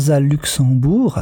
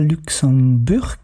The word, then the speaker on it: Luxemburg: Et je vous prie de bien vouloir m'excuser de toujours prononcer le nom de Rosa Luxembourg, alors qu'il s'agit bien de Rosa Luxemburg.